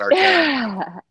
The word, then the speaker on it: Arcand: Arcand. I don't really accentuate that D very much. I say Dallas Arcand. So it sounds like I say it without the D, but there's a D at the end of it.